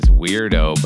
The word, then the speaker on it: Wrdo: His name is Weirdo, but it's spelled W-R-D-O.